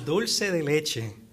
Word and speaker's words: dulce de leche: Regarding desserts, what is dulce de leche, and how can I make it from supermarket ingredients? And I hope pronounce that correctly for us, if you would, too. – Yeah, dulce de leche.